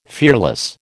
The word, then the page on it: fearless: American English